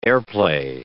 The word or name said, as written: airplay